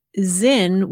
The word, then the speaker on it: Sinn: And you explain that the word Zin, which is spelled capital S-I-N-N, is pronounced as though the S were a Z and is the German word for meaning,